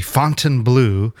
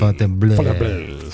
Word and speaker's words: Fontainebleau: And we went over to the Fontainebleau, this brand new hotel casino. – It's pronounced Fontainebleau. – Fontainebleau.